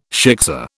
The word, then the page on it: shiksa: British English